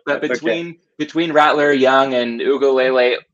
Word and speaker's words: Uiagalelei: I'll try to get the name right. DJ Uiagalelei, I believe. – Uiagalelei, yeah. – Okay, there we go.